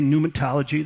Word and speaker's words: pneumatology: Okay, pneumatology, there's a silent P there. I'll let you figure out where it goes.